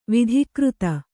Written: ವಿಧಿಕೃತ

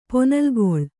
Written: ಪೊನಲ್ಗೊಳ್

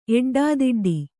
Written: ಎಡ್ಡಾದಿಡ್ಡಿ

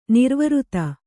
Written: ನಿರ್ವೃತ

